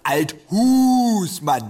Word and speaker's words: Althusmann: Althusmann, Betonung auf der zweiten Silbe.